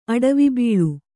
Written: ಅಡವಿಬೀಳು

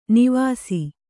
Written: ನೇವಸ

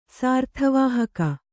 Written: ಸಾರ್ಥವಾಹಕ

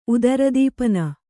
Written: ಉದರದೀಪನ

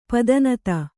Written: ಪದಿನೈದು

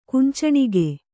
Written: ಕುಂಚಣಿಗೆ

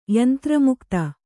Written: ಯಂತ್ರಮುಕ್ತ

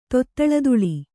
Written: ತೊತ್ತಳತುಳಿ

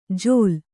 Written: ಜೋಲ್

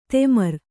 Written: ತೆಮರ್

